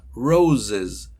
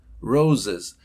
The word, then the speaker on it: roses: Y esta que tiene dos z, que es plural de rosa, roses, roses. Ahora voy a decirlo todo y escuchen, z, escuchen, gives.